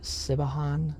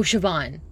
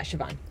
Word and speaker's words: Siobhan: Sibahan. – Shiv. Oh, Siobhan. – Siobhan. – Yeah, Siobhan.